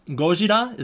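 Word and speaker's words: Gojira (ゴジラ): So like and fans might get upset about my pronunciation, but it's Gojira is the Japanese word. And then back in current day, the way we would translate that, the way we translate the characters is Gojira. So it's Gojira or Gojira, if you want to pronounce it like that.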